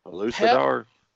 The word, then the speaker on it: Pellucidar: And in Korak, you had the, how do they pronounce it? – Pellucidar. – Pellucidar, which is the world.